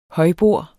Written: højbord

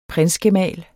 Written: prinsgemal

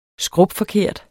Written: SKRUPFORKERTE

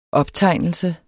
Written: optegnelse

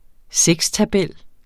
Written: sekstabel

